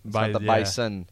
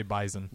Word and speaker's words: Bison: That's how they say it, isn't it? Mm-hmm. The Bison. Bison. – Yeah, they say Bison. – Bison with a Z.